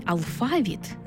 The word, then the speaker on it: алфавіт: Наголос у слові «алфавіт» на другому складі основний у нашій мові, бо збігається з грецьким «алфавітон», від якого і походить українська лексема.